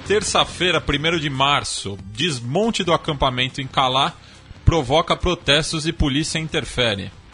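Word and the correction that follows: Calais: Calé. O Chico já está ali condenando a sua pronúncia.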